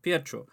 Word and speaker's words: Pietro: Pietro.